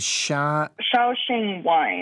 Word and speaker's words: Shaoxing: How do you pronounce it? – Shaoxing wine.